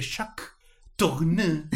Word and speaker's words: Jacques Tourneur: Y bueno, joyitas sueltas como Cat People de Jacques Tourneur.